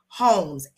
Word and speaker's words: Holmes: My name is spelled C-A-N-D-A-C-E. It's spelled like Candace, but pronounced Candace Holmes, H-O-L-M-E-S.